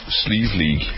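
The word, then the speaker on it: Slieve League: how do you pronounce it again? – Sleeve League.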